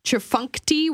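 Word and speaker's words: Tchefuncte: Durschkomponiert, D-U-R-C-H-K-O-M-P-O-N-I-E-R-T. And my favorite, Chafunkti, which starts with a silent T.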